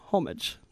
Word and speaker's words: homage: Would you say homage or homage? – Homage. No, homage. Homage. Like fromage.